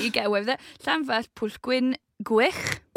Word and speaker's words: Llanfair: So the first bit is Llanfair. – Llanfair.